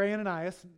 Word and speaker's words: Ananias: This is the Lord told Aeneas, or Ananias. I've been saying his name wrong.